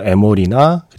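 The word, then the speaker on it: Amoreena: 유어성, 아모리나라고 했는데 뭐 에모리나라고 발음하는 게 좀 더 낫겠네요. 예, 유어성, 그리고 이어서 에몰이나 그리고 지금 타이니 댄서까지 예, 세곡 듣고 왔습니다.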